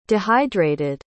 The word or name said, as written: dehydrated